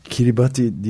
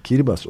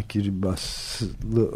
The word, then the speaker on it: Kiribati: Dünyanın en ilginç filmlerinden bir tanesi, iklimle ilgili bahsedecek olursak, Amerika Birleşik Devletleri'ndeki bir Kiribati diye bir yer var ya, Kiribas okunuyormuş aslında.